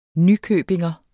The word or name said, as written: nykøbingenser